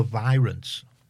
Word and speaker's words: The Virants: A band called The Virants. – Okay. – Pronounced V-I-Rants. That's what it's...